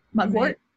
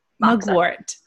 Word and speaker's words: mugwort: And am I saying this right? Mungwort? – Mugwort? – Mugwort. – Yes.